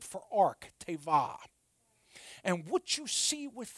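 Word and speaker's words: teva (תבה): But the word ark in the original language is pronounced teva.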